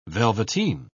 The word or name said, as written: velveteen